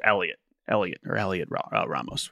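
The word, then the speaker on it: Heliot Ramos: It is just Elliot Ramos, which is always that throws me off. Puerto Rican born Elliot Ramos, 24-year-old outfielder this year.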